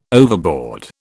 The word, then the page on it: overboard: British English